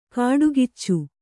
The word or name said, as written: ಕಾಡ್ಗಿಚ್ಚು